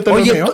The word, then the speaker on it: Ptolomeo: El primero es Claudio Ptolomeo. Nunca he sabido bien cómo se pronuncian las P antes de las palabras. – No se pronuncia. Es como psicólogo. No se pronuncia.